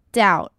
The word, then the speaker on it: doubt: 首先我们看到 doubt 要注意的是字尾的 B 其实不发音 doubt